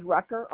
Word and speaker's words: Rucker: My last name is Rucker, R-U-C-K-E-R.